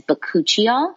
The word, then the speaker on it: bakuchiol: And it is a natural ingredient known as bakuchiol, which I'll spell, but it's B-A-K-U-C-H-I-O-L. So bakuchiol, just because it doesn't necessarily sound how it's spelled.